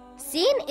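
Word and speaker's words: Sin (سين): Sin is a light letter and should not be pronounced heavy. Sin makes a similar sound in English to the letter S in the word sun or the word salad.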